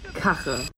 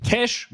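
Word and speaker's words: Cache: Das wird nicht Kache ausgesprochen. Diese Aussprache Kache ist auch nicht korrekt. Es gibt genau zwei Varianten, es im Deutschen richtig auszusprechen. Cash, wie das englische Wort für Geld, und Cash ohne E am Ende.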